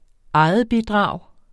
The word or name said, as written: egetbidrag